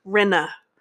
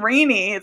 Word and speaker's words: Rinna: Rinna. – Lisa Rinna. I cannot say. I've been saying Rainy.